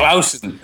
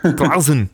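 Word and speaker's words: Clason: Klassen, C-L-A-S-O-N. I don't know how to pronounce it, but that's his name. – Klausen. – Klausen.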